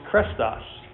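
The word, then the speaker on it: krestos (χρηστός): A word that is pronounced krestos.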